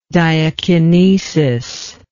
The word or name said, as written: diakinetic